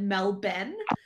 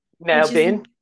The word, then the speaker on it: Melbourne: Yeah, but you have to pronounce it like an Australian and call it Melben. – Melben? – Is not at all how it's spelled, but you know, you got to put the Aussie accent.